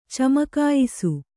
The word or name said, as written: ಚಮಕಾಯಿಸು